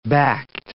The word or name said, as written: backed